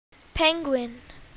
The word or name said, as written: penguin